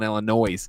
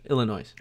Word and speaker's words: Illinois: I don't believe you pronounced the S. – That's true, I know. – Okay. In what word? – Illinois. – Oh, I said Illinois.